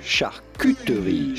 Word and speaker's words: charcuterie: You do want to insist on the Q part, and it's Q, not coup, because yes, it is a French word and a name of French origin, and the French pronounce the U, charcuterie, charcuterie. Now in French, you'd say charcuterie, charcuterie, charcuterie. – I just call an adult lunchable. – But in English, charcuterie.